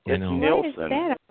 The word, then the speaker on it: Nielsen: So, ladies and gentlemen, joining us here on Paratalk Radio tonight is Mr. Now, it's Nielsen or Neeson. How do I say it so I don't screw it up? – Too late. – It's Nielsen.